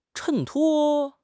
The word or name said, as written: 衬托